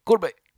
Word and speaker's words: Gorobei: Gorobei. – They say it a little faster. It's like Gorobei.